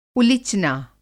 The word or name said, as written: उलिचना